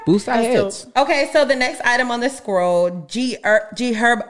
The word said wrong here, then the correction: Herbo: I always say his name. – With the car? – Yeah. Okay, so G Herbo. – Herbo. – I always want to make him a herb.